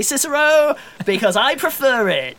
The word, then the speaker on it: Cicero: Cicero. Cicero. Ah! Who's asking? – I think it's pronounced Kikero, actually, yeah.